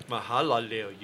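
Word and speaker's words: Mahalalel: Mahalil, the blessed God. – Mahalalil. You missed a syllable in there.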